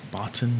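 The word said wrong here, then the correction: Botton: is that how he pronounced his name? – Bouton.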